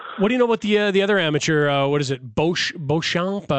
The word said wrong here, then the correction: DeChambeau: I'm saying it wrong. – Bryson DeChambeau. – DeChambeau, yeah.